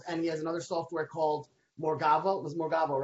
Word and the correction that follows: Morvago: right? – Morvago. – Morvago. I said it right before the call, and I messed it up now. – With a V, yeah.